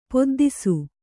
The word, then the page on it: ಪೊದ್ದಿಸು: poddisu